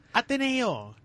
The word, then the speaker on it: Ateneo: Nee, yung pagkakonya, just Ateneo. He's taught at Ateneo. – Okay, fine. – Not the Ateneo.